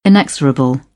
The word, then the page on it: inexorable: British English